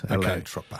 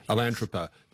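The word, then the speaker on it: Atlantropa: and his scheme he called Alantropa. Have I pronounced that right? – That's right. Alantropa. – Okay. Alantropa.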